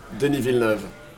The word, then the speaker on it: Denis Villeneuve: The director, Denis Villeneuve? – Denis Villeneuve.